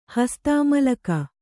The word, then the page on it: ಹಸ್ತಾಮಲಕ: hastāmalaka